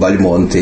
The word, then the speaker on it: Бальмонт: У него были братья, у него ныне живущие Бальмонты или Бальмонты. Как быть? И здесь я бы сказал так, что фамилия произносится Бальмонт. Она происходит от, видимо, от литовского «балмут», ударение на первом слоге.